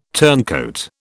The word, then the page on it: turncoat: British English